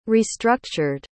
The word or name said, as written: restructured